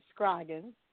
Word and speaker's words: Scroggins: You said it right, Scroggins.